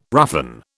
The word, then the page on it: roughen: British English